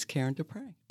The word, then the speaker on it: Karen Deprey: It's Karen Deprey.